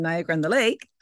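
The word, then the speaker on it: Niagara-on-the-Lake: And of course, you want to go check out the product at The Edit in 223 King Street in Niagara on the Lake.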